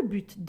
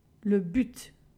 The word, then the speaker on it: but: La préposition afin de a comme synonyme pour ou dans le but de. Dans le sud de la France, on prononce le T, le but. Au Québec, par exemple, on ne le prononce pas, le but.